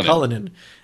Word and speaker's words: Cullinan: Cullinan.